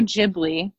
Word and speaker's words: Ghibli: Studio Ghibli,